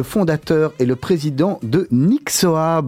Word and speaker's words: Nyxoah: Nixoa, ça se prononce Nixoa. Nixoa, avec le H, c'est important c'est quoi Nixon dans le nom Expliquez-nous euh, déjà.